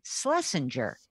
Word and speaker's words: Schlesinger: So we just say, instead of Schlesinger, we say Schlesinger. I said, one way to remember it is like S-L, like S-L, pretend there's no C-H there. And it's a soft G, like a J, not a GER.